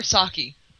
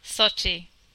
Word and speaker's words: Sochi: Is it Sochi or Saki? – Sochi.